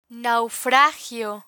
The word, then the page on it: naufragio: Spanish